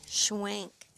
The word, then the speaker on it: Schwenk: are here with Donna Scwenk.